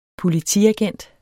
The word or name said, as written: Politiagent